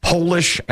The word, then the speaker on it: Polish: It's the only word that when you change it from being lowercase to capitalized, it changes its meaning and its pronunciation. It'd be hard to think of. It's Polish and polish. It's spelled P-O-L-I-S-H, Polish and polish.